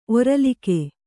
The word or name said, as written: ಒರಲಿಕೆ